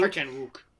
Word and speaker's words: Park Chan-wook: I think Park Chan-wook. Yeah. – The W is not pronounced.